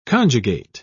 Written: conjugate

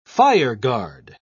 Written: fireguard

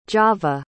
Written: java

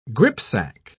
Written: gripsack

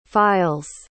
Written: files

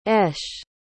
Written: eshe

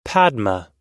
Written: padma

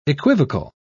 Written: equivocal

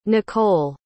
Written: Nicolle

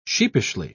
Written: sheepishly

